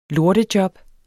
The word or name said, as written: lortejob